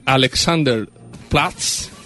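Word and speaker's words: Alexanderplatz: Esto ocurrió en Berlín, en Alexander... Platz, espérate, lo voy a pronunciar bien. Alexander Platz. Sí, lo pronuncia bien.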